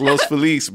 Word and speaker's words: Los Feliz: At least say the name correctly. – Say the name correctly. – I think I say it that way. – It's Los Feliz, bro. – It is Los Feliz.